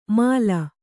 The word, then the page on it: ಮಾಲ: māla